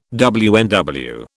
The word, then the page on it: WNW: British English